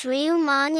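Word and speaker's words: Ryumon: "That's pronounced Ryuman in Japanese.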